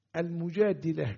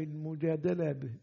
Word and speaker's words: المجادلة: هل تنطق المجادلة كما نطقناها بكسر الدال أم المجادلة بفتحها؟ الأظهر أنها تنطق بالكسر،